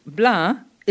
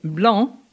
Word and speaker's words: blaa: Incidentally, Bla is spelt B-L-A-A.